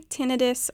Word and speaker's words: tinnitus: We say tinnitus only because itis suggests that there's an inflammation of something.